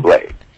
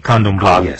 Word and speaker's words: Candomblé: And pronounce that again, Candomblé. Pronounce that again. – Candomblé. – Okay, Candomblé. – Candomblé. – Yes.